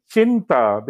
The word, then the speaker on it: Cinta: The name is actually Cinta, because in Italian, a C that precedes an I is pronounced as a ch.